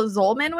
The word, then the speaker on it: Zolman: We have Paul Zolman with us.